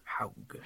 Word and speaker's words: Haug: It's Surly's loss of founding brewer Todd, and we've been going on about how to say his last name. – Haug. – Haug? I don't know. – That's what I'm going with. – Haug. H-A-U-G. – H-A-U-G. Todd Haug.